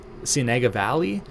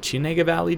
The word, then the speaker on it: Cienega Valley: The main component of this comes from the Wurz Vineyard, which is in the Cienega Valley, or Cienega Valley, depending on who you're talking to. I feel like in California, everything is just pronounced phonetically, regardless of what the of what the origins of the word are.